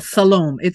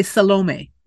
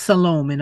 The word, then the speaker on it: Salome: To the point where her and Rooney's character at the end, Shalom. I'm sorry. I can't. – Salome. A lot of people would say Salome, but okay. It's pronounced Salome in some Mennonite cultures and in our film.